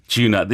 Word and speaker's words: Djuna: Djuna. Okay. – Yeah, Djuna.